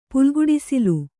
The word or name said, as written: ಪುಲ್ಗುಡಿಸಿಲು